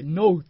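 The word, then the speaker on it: 喃: ノウって読むねん俺こんな読み方初めて知ったわと思っていやーすごいですよね。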